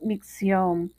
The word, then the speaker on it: micción: Y la palabra de hoy día es micción. Es una palabra aguda, se acentúa en la última sílaba debido a que termina en N.